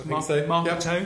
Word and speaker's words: Marketo: Marketo, I think that's how it's pronounced. – Marketo. Yep.